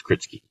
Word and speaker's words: Kritsky: I was adding too many vowels. It's simple. It's Kritsky.